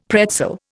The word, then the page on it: pretzel: Indian